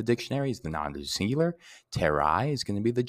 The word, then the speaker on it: terrae: So, for example, if we had the word like uh, earth, which is terra, terrae, aka terrae, that AE is pronounced I.